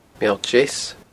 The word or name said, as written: Meall Deas